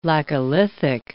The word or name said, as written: laccolithic